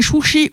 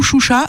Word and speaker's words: Chouchi: Déjà, c'est Chouchi ou Choucha, ça dépend aussi là voilà. De quel côté de la frontière vous êtes.